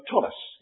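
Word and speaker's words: tallit: This is called number one, a Tolith, or you might pronounce it a Tolus. It depends on what part of the Jewish people you belong to.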